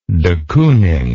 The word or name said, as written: de Kooning